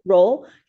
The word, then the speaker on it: Rowell: Um, and then the one new person uh, is Victoria uh, Rowell. – Roll. Roll. – Roll. How do you say? Rowell. Roll uh, as Paula.